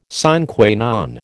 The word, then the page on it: sine qua non: American English